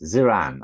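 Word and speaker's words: Ziran (自然): And then next up is Ziran. I hope I'm pronouncing that right. – Very good pronunciation. Yes, Ziran.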